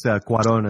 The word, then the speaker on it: Cuarón: I think it's Cuarón is the way you would say his name in Spanish.